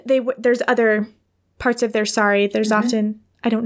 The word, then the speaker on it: sari: and in the Indian culture, they often wear sari? – Mm-hmm.